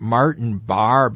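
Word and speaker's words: Martin Barre: Yeah, he's coming, and Grammy winner, and I hope I pronounce his name right, Martin Barr, B-A-R-R-E, is right.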